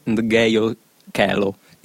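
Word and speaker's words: Ndegeocello: Infatti hai sbagliato. – Ho sbagliato e lo sapevo. Correggiamola definitivamente, così non sbaglieremo più. – C'è scritto anche sul suo primo album, si dice Michelle Ndgeyo Kelo.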